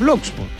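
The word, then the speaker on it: Blockx: Porque se escribe B-L-O, B larga, ¿no? Sí. B alta, L-O-C-K-X. – Z. – No. No. Se cierra con X. – Entonces Blocks nomás. – Es Blocks.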